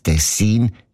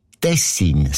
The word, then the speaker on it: Tessin: Aber der SRF-1-Hörerin Regula Stern-Grieser von Locarno ist aufgefallen, dass gewisse Leute statt Tessin Tessin sagen, also Betonung auf mehr, mit einem kurzen I.